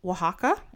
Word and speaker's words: Oaxaca: I started watching this video and it was about this woman who has her own restaurant in Oaxaca.